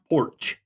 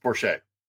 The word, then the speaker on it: Porcher: Pronounced Porch. – No, actually, it's spelled Porcher, but it's pronounced Porsche.